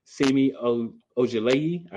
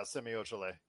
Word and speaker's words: Semi Ojeleye: Some good things that Milwaukee did was they signed Semi Ojeleye. I believe that's how you say it. – Yes.